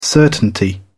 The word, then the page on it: certainty: British English